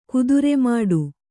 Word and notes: ಕುದುರೆಮಾಡು: Kannada

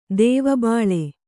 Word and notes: ದೆವ್ವಬಾಳೆ: duvva bāḷe

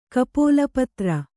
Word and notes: ಕಪೋಲಪತ್ರೆ: kapōlapatre

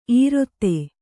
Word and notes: ಈರಿತ: Kannada

